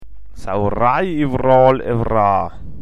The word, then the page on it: Sawwerei: mundart